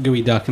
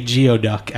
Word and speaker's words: geoduck: We were just talking about gooey duck, and I was also excited that you pronounced it prior to me, because I usually say geoduck at first, just phonetically seeing it spelled out.